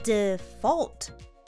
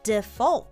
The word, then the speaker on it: Default: 短い言葉ですが発音に要注意。 DFLT これらを短くつなげて発音してみましょう。 Default。こんな感じで Repeat。 Default。